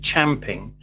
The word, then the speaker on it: champing: Hi, I was taught that the expression used champing, where champ rhymes with ramp in British English, and that it was describing a horse grinding its teeth on the bit in its mouth in frustration.